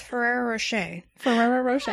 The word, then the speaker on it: Ferrero Rocher: Also, it's Ferrero Rocher.